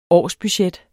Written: årsbudget

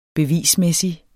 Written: bevismæssig

